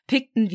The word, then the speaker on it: Pickton: Also nicht Picton wie Pick mit G, sondern P-I-C-K trotzdem wird es halt so ausgesprochen und ich finde es sehr passend tatsächlich.